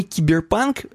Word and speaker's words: киберпанк: И здесь вот вся статья, она как бы киберпанк эм, с, с ударением больше на панк.